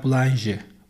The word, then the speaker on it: Boulanger: Pardon, Bay Boulanger, Boulanger diye okunuyormuş Fransızca'da.